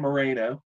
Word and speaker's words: Marino: It's actually spelled pronounced Marino. I'm 99.9% sure.